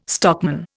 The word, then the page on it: stockman: Indian